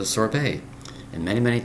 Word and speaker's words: sorbet: Today's word is sorbet. Sorbet, spelled S-O-R-B-E-T. Two syllables.